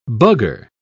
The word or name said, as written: bugger